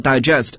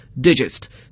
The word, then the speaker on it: digest: 因为 s 之后要失去爆破，那么 digestive 消化的和消化有关的它一定来自于动词 digest 消化的动词，但是说起来呢也巧 digest 这个词呢在英语中它应该算是一个 homographs 同形异音异义词，大家都知道它做名词时还可以表示文摘，但这个文摘呢就不能读作 digest 而要读作 digest, 所以拼写相同可是发音词性格不相同 digest digestive。